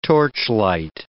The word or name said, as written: torchlight